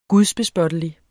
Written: gudsbespottelig

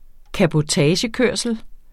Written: CABOTAGE

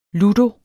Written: ludo

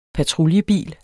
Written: patruljebil

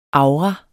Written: aura

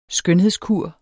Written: skønhedskur

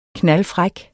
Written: knaldfræk

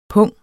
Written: pung

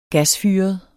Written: gasfyret

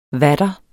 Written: vater

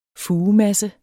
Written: FUGEMASSEN

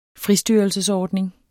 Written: fristyrelsesordning

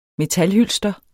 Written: metalhylster

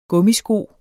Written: gummisko

